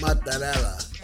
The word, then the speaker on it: mozzarella: I got mozzarella. – Oh, you got mozzarella. What? – Mozzarella. – Oh, that's how you say it? – Yeah.